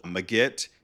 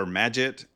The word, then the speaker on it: Magit: there's this package called Magit or Magit. Like everyone debates how to pronounce it, but it's like kind of like magic, but with the T at the end for Git.